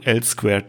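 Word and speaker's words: L²: Neue Technologien und Lösungen sollen unter dem Namen L hoch 2 Technology, äh, ausgesprochen L Square Technology, am Markt auftreten.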